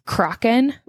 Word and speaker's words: Crochan: So the first one is Crochan witches. This is spelled C-R-O-C-H-A-N.